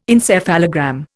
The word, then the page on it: encephalogram: Indian